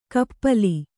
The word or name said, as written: ಕಪ್ಪಲಿ